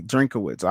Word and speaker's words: Drinkwitz: Okay, Drinkwitz.